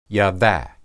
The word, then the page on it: yada: Hebrew